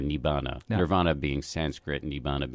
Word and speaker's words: nibbana: Pseudo-nirvana, or nibbana would be the Pali pronunciation.